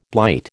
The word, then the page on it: blight: American English